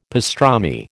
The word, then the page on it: pastrami: American English